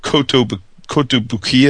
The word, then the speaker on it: Kotobukiya: Kotobukiya, is that how you say it, Vince? Mm-hmm. Yeah.